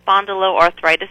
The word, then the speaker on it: spondyloarthritis: But you had another term in here that I've never even seen before, spondyloarthritis. I hope I'm pronouncing that correctly. – Yes. So there's a condition called the category of spondyloarthritis.